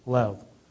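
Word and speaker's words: lev (לב): Now, the word heart in the Old Testament scripture is the Hebrew word lev, but it's pronounced L-E-H-V, lev.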